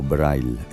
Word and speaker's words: Breil: o Breil, pronunciado a la alemana, considerado uno de los primeros profesionales de bandas sonoras.